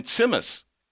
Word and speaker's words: tsimmis: And simus. – I've never heard of that. – T-S-I-M-M-I-S.